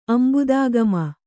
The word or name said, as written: ಅಂಬುದಾಗಮ